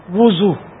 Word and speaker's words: وضو: وضو نہیں ہے, وضو واو پر پیش ہے.